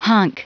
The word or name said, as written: hunk